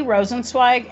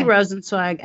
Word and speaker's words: Rosenzweig: So it's E Rosenzweig, and you might say, how do you spell that? So E-R-O-S-E-N-Z-W-E-I-G at bentley.edu. And Bentley is bentley.edu. Okay. E Rosenzweig at bentley.edu.